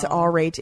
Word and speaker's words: Aurate: Aurate. I'm pretty sure that's how you pronounce it. Is it? – I don't know. – It's Aurate. It's A-U-R-A-T-E.